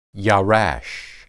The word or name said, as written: yarash